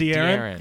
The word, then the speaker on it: De'Aaron: Is that how to say it? Or is it De'Aaron? – Yeah, I think De'Aaron.